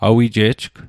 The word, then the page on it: awije'jg: Mi'gmaq